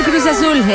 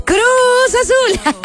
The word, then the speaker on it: Cruz Azul: Con el Cruz Azul en el año 1998. A ver, no lo dije bien. ¡Cruz Azul! En el año de 1998.